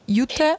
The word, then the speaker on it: jute: Do you say that? Jute? – Hemp. I think it's hemp. – But it's spelled J-U-T-E. – Jute. – Jute, yeah. Jute.